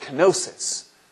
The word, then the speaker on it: kenosis: K-E-N-O-S-I-S, kenosis, just like it sounds, it's spelled that way.